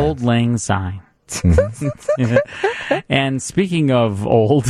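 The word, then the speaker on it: Auld Lang Syne: How do you pronounce it? – Pronounce it. – Old Lang Syne. – Syne? – S-Y-N-E. – Oh, Syne.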